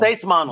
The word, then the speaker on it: Seis Manos: I'm Latino, so I better say it right. Six. – I know. – Seis Manos. Yeah.